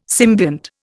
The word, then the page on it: symbiont: Indian